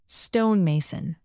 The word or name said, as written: stonemason